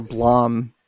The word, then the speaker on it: Blame: And have you ever read the manga Blame? – I have not. – I can't read. – Okay. All right. Obviously. Blame or Blom, people pronounce it differently.